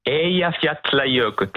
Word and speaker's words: Eyjafjallajökull: Me lo sono meritato a provare addirittura a pronunciare, ripetilo per favore. – Eyjafjallajökull. – Perfetto.